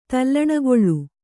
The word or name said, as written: ತಲ್ಲಣಗೊಳ್